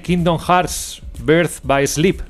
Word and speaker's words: Kingdom Hearts Birth by Sleep: Vamos con la preview de Kingdom Hearts Birth by Sleep.